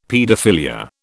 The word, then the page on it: paedophilia: British English